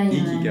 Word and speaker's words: Ikigai: c'est l'ikugai, est-ce que je prononce bien Ikigai. Ouais. Ikigai, euh, euh, vraiment à, l'intérieur de, de, soi.